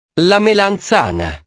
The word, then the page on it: la melanzana: italiano